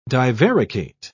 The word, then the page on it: divaricate: British English